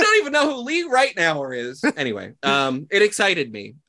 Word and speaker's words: Ritenour: Lee, L-E-E. And then, yeah, Ritenour, I'm pretty sure is how you pronounce it. R-I-T-E-N-O-U-R. – Okay, I got him. – That's like how an Australian says right now.